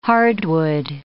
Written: hardwood

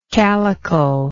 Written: calicular

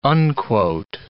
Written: unquote

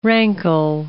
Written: rankle

